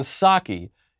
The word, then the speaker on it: Psaki: My favorite comment from Friday is from Eric Gomez, who says that the P in Psaki is silent.